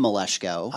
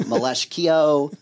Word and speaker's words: Maleshko: you actually said it correctly, too. So props. Because we, we get the whole gamut Maleshko, Maleshkio. Yeah, also.